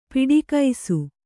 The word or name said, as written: ಪಿಡಿಕೈಸು